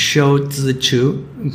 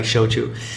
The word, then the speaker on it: Shou Zi Chew: Oh, by the way, if you, the CEO of TikTok, Shou Zhichu, it's funny, whenever they say his name, they always leave out the Z. It's Shou Zhichu, but they take out the Z sometimes. So now it's like Shouchu.